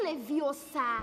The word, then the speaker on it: Leviosa: E você está falando errado. É Leviosa. E não Leviosa.